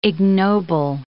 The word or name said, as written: ignoble